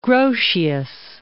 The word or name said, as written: Grotius